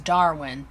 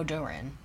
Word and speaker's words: Darwin: And Jimmy says, dude, it's Darwin. There is no Durin.